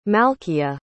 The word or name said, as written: malkia